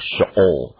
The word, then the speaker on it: Sheol: He says, I will go down mournful to my son to Sheol, or Sheol, if we pronounced it in the Hebrew.